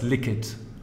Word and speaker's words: LIQID: Habe ich das überhaupt richtig ausgesprochen, LIQID oder ist das LIQID? – Achso, ich dachte, du meinst jetzt das, das ist dir aber auch gut gelungen und ja, wir heißen LIQID.